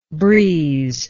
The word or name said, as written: breezelike